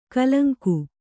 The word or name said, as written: ಕಲಂಕಿ